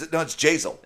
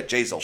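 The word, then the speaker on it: Jasiel: JCL, like JCL Carrero? – It's Jazel. – Is that how it's pronounced? – Yeah, Jazel.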